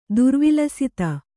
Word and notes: ದುರ್ವಿಲಸಿತ: Kannada

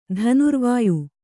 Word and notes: ಧನುರ್ವಾಯು: dhanurvāyu

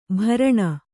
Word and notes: ಭರಣ: kannada

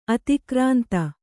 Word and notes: ಅತಿಕ್ರಾಂತ: Kannada

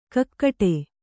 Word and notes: ಕಾಕಡೆ: kākaḍe

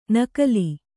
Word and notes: ನಕಲಿ: Kannada

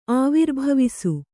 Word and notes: ಆವಿರ್ಭವಿಸು: Kannada

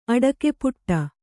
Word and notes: ಆಟಕಪಟಿ: āṭakapaṭi

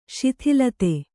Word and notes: ಶಿಥಿಲತೆ: śithilate

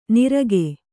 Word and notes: ನಿರಗೆ: nirage

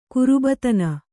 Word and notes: ಗರ್ಭಾಧಾನ: Kannada

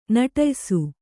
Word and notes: ನಿಟ್ಟಯಿಸು: kannada

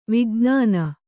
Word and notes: ವಿಜ್ಞಾನ: vijṇāna

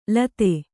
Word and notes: ಲೂತಿ: lūti

